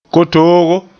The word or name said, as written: kotoogo